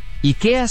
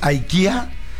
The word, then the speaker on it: IKEA: En sueco, IKEA se pronuncia IKE con acento agudo A. – IKE. – IKE. – IKE. Entonces, IKE en Suecia y seguramente en Europa ha de ser IKE. En Estados Unidos, IKEA.